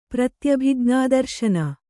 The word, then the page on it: ಪ್ರತ್ಯಭಿಜ್ಞಾದರ್ಶನ: pratyabijñā darśana